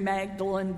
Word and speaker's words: Magdalen: It's really Magdalen as in Mary Magdalene, but it's the way the British pronounce it.